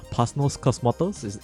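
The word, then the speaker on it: Panos Cosmatos: And I think, not what a better time than Halloween for us to be talking about this latest offering from Pasnos Cosmatos. Did I get the name right? – Yeah.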